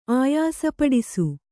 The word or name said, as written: ಆಯಾಸಪಡಿಸು